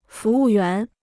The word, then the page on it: 服务员: fú wù yuán